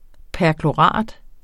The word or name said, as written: perklorat